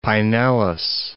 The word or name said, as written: Pinellas Park